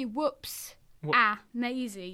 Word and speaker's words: Whoopsie Maisie: Because the way that it should work, it should be whoops-a-maisy. It's like three separate words. – Ah. – To rhyme with whoops-a-daisy.